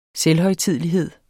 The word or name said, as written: selvhøjtidelighed